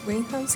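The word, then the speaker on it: Winehouse: Y oh, bueno, cuando falleció Amy Winhouse, ¿sí se dice así Winhouse? Winhouse. sí.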